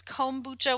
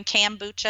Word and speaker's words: kombucha: You're definitely saying it right. So it's kombucha with an O. You get people in the Midwest going kombucha and they spell it with an A, but that's not exactly right.